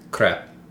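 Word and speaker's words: crêpe: Their vowel sounds are a little different from ours, so it would sound like crepe. It's like kind of in the middle between A and E.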